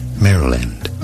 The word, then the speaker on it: Maryland: It is said as Maryland, Maryland. Stress on the first syllable.